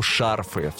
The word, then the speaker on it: шарфы: И как у нашей Марфы есть в полоску «шарфы», в слове «шарфы» ударение на букву «а».